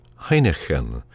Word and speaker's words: Heinichen: That's spelled H-E-I-N-E-K-E-N. But this is the 18th century German composer Heineken, and that's spelled H-E-I-N-I-C-H-E-N. They're very similar sounding, but very different in spelling and so on.